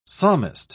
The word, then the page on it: psalmist: British English